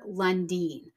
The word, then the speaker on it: Lundin: So for the record, it should be Dr. Lundeen. I think I said Dr. London in the episode, and that is not correct historically.